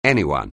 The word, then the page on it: anyone: anglais